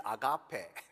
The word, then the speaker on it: agape: God's love, in Greek, agape, in English, and in actual Greek pronunciation is agape, has no boundaries or limitations.